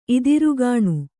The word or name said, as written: ಇದಿರುಗಾಣ್